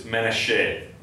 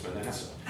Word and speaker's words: Manasseh: Whereas Moses says it's Menasheh. Oh, I can tell him. You're saying it wrong. It's Manasseh.